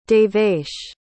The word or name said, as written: devesh